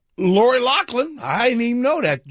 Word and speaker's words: Loughlin: By the way, Loughlin, apparently. I've been saying it wrong my whole life as well. It's Lori Loughlin.